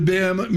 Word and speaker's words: BAM: Build a stronger tomorrow with BAM Mutual. Paul insists I spell it B-A-M.